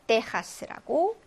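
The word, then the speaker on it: Texas: 자, 그리고 또 하나가 미국의 텍사스 있죠? 그거를 떼하스라고 얘기를 합니다.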